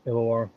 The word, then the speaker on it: Illawarra: Illawarra. Did I say that right? – Yep, Illawarra.